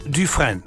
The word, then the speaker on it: Dufresne: Und der schreibt sich folgendermaßen: D-U-F-R-E-S-N-E. Etwa ausgesprochen Dufresne. Dufresne. – Ja. – Etwa so, ja? – Ja.